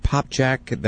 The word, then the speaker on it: Popcak: We're talking right now with Dr. Greg Popchak. That's spelled P-O-P-C-A-K.